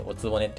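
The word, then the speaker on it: 御局: お曲と書いておつぼねって読むんだけどさ、放送局の曲。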